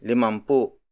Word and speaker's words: limampu: Up next is limampu.